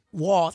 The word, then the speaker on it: Walz: Walz.